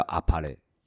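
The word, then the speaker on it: あっぱれ: 对对，阿帕雷其实它的意思就是完美。嗯，那汉体字，日文汉体字，它是天晴，就是把我们的中文的晴天倒过来。哦，所以我们的其实中文的话是叫天晴串酒厂。嗯，那天晴的日文发音叫阿帕雷。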